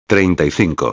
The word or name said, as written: 35